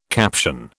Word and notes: caption: British English

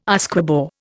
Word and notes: Usquebaugh: Indian

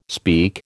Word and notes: speak: American English

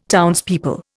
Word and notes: townspeople: Indian

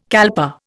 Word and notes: kalpa: Indian

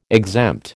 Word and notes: exempt: American English

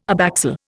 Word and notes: abaxial: Indian

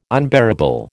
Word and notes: unbearable: American English

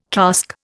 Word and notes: casque: Indian

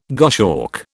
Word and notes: goshawk: British English

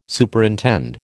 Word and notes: Superintend: American English

